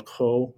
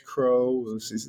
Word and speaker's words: Cros: You know, American pronouncing Charles Cros, you know, or Charles Cros. I've even heard different pronunciations in parts of France and the southern regions. There's a Cros, I think. – Cros, yeah. Yes. I wondered if the S was silent or not.